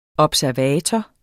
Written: observator

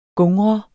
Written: gungre

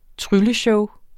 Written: trylleshow

